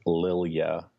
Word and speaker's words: Lilja: Yes, so it's Chris, and then my last name is Lilja, L-I-L-J-A.